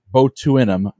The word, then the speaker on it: botulinum: Baney, in 1989, the FDA approved the use of the now wildly popular botinium neurotoxin. Sorry, botulinum neurotoxin to treat two eye conditions though now it's widely used for other purposes.